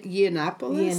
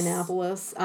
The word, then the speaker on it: Yiannopoulos: Ye- Yiannopoulos. Yiannopoulos.